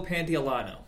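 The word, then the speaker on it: Pantoliano: What is Joe Pantolano? – Okay, thank you.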